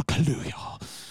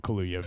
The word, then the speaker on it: Kaluuya: and perhaps Daniel Kaluuya.